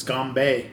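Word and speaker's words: Ganbei (干杯): It's Ganbei. – Yeah. Ganbei. Ganbei. How do you pronounce it, Phil? – My Mandarin sucks, but... – it's Ganbei.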